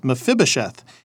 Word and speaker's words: Mephibosheth: Jonathan had a disabled son named, it's a little hard to pronounce, but it's Mephibosheth.